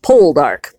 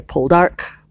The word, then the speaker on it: Poldark: And um, I always want to say it Poldark because I'm an American and I always want to put the emphasis on the first syllable. Um, but it's, I've been noticing that the characters on the show always say Poldark, Pull Dark, uh, which it's interesting.